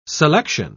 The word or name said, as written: selection